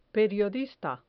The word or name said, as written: periodista